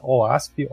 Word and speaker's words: OWASP: Se você não conhece, procura OASP, O-W-A-S-P. Acho que é isso, né, gente? Falei certo, né? – É.